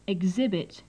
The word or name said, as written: exhibit